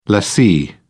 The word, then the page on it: lessee: British English